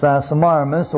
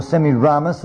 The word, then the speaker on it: Semiramis: Now so happens that Nimrod was married to a woman whose name was Semiramis, or Semiramis, as some people prefer to pronounce it.